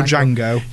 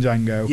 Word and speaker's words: Django: D-J-A-N-G-O. The D is silent.